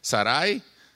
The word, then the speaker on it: Sarai: Y a Sarai, que algunos dicen Sarai, no sé por qué, porque no tiene acento en la I. Sarai le cambió el nombre a Sara, le quitó la I y eso le llamó Sara.